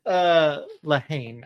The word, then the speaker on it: Lehane: Lehane, I believe is how it's pronounced.